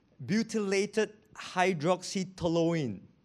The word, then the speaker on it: butylated hydroxytoluene: You want to know how to pronounce it? I'll try slowly. Butylated hydroxytoluene. Butylated hydroxyanisole. BHT, BHA.